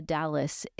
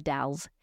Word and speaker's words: The Dalles: It's not pronounced the Dallas. It's called the Dalles.